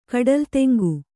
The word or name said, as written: ಕಡಲ್ತೆಂಗು